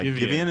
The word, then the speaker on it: Giveon: Is that how you say his name? Giveon. – Yeah. Giveon is, he is mad talented.